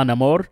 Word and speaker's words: Namor: My guy over here playing Namor. – Uh, oh yeah, which they are. Oh, uh, yeah. Uh, the pronunciation is now Namor.